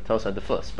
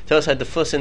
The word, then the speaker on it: Tos HaDefus: And the Yerushalmi, the way the Aruch HaShulchan understands this, is that there's really a Tos HaDefum in the Yerushalmi. So Tos HaDefus, but you said it wrong. Tos HaDefus in the Yerushalmi,